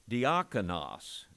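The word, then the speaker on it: diakonos (διάκονος): The word deacon, diakonos, sounds like deacon, because basically when we say the English word deacon, we're pronouncing the Greek word diakonos.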